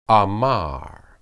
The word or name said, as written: amar